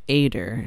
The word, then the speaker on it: Ader: And Villani's like, this is Mistress Ader. And Clariel's like, Adder? Like the snake? And she's like, no, Ader. Like I just pronounced. Jesus Christ. Ader. Not Adder. Ader.